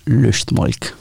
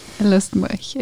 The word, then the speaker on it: Lustmolch: if, you, if you're German, it's Lustmolch. – Lustmolch, exactly.